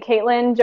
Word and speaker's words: Caitlin: I'm under the name Caitlin Johanna, J-O-H-A-N-N-A, and Caitlin is spelled C-A-I-T-L-I-N.